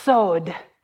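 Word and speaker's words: sod (סוד): The word that is used there is C-O-W-D, but it's pronounced sod.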